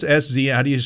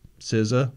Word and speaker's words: SZA: What's SZN? How do you pronounce SZN? That artist. – SZA? SZA? – SZA.